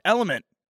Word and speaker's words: LMNT: Yeah, Element, pronounced Element. Hello.